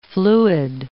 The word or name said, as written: fluid